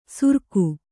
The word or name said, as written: ಸುರ್ಕು